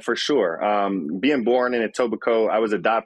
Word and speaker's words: Etobicoke: No, it's Etobicoke.